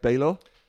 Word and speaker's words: Baylor: Is it pronounced Baylor? – Baylor.